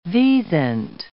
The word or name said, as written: wisent